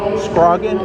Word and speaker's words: Scroggie: Scroggie? S-C? – Scroggie. – How do you spell that? – S-C-R-O-G-G-I-E.